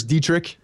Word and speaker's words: Dietrich: Is it Dietrich or Dietrich Enns? – I'm pretty sure it's Dietrich. – Dietrich Enns. Okay.